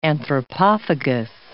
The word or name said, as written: anthropophagus